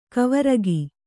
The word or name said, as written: ಕವರಗಿ